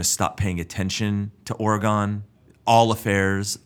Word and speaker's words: Oregon: But unfortunately, there's a lady up in Oregon named Kathy Boone who died. Let me stop you there. It's Oregon. – Oregon. – Come on. – I'm from Texas. Listen. I pronounce it however I want.